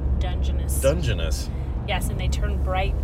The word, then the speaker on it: Dungeness: I'm going to get a couple of crabs to put in there, but I will just go buy Dungeness crabs from the... – Dungeness? – From the meat market. – Is that how you say it? – Mm-hmm. – I didn't know that.